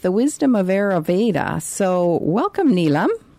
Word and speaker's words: Ayurveda: How do you pronounce, like, it's Ayurveda, but like I say, I and your and Veda. Ayurveda.